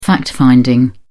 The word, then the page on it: fact-finding: British English